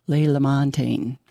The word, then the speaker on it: Ray LaMontagne: I can never say his name. Ray LaMontagne, that's how it's said.